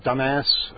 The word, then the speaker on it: Dumas: We've passed through Cactus, Texas, Dumas. I know that's not how you pronounce it. D-U-M-A-S. How do you pronounce that? – Dumas. – All right. You know, that's not too far off.